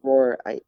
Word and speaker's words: Rohr: A friend of mine is reading and listening to and taking in some work by a gentleman named Richard Rohr, R-O-H-R, I believe. – Yeah. – I hope I'm not misspeaking. – Nope, that's how you say his name.